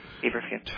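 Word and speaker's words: feverfew: Feverview. Am I pronouncing it right? – Yes, feverview.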